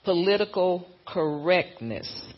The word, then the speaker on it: political correctness: So the gospel of peace is a threat to the political correctiveness. I know I said that wrong. Let me say that right. Political correctness threatens our speech, our free speech, and it threatens our peace.